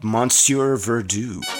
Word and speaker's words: Monsieur Verdoux: But it's called Monsieur Verdoux. – Excellent, excellent pronunciation, by the way.